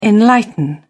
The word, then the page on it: enlighten: British English